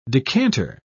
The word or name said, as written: decanter